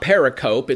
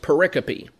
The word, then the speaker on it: pericope: So this pericope, and the word pericope here, it looks like periscope, only it's missing the letter S, and it's not pericope, it's pronounced pericope.